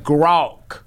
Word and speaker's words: Grok: It says, I pronounce Grok, G-R-O-K, as G-R-A-W-K. Gronk. Rhymes with hawk or talk.